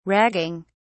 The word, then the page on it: ragging: British English